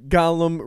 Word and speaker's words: Gollum: And Gollum runs past him, and he's like, "Wait, I'm invisible." I thought I said it wrong the first time. – Yeah, no, you didn't.